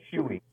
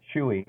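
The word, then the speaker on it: Schewe: It's S-C-H-E-W-E, and it's pronounced Chewy, right? – Chewy. – Yeah. – Chewy, yeah.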